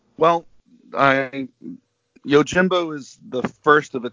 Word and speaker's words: Yojimbo: Yojimbo.